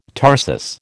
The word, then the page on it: tarsus: American English